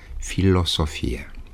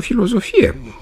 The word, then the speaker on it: Filosofie: Ați remarcat probabil felul în care am pronunțat. Filosofie. De ce nu filozofie? Cum e mai ușor și cum, de altfel, scriu și pronunț majoritatea vorbitorilor. Marele lingvist Alexandru Graur explica faptul că pronunția filozofie și nu filosofie își are originea în greaca modernă.